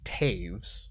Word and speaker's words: Toews: It is pronounced Taves. – Taves? – I'm not going to blame you for that one, because T-O-E-W-S probably shouldn't be pronounced Taves.